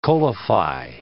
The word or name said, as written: coalify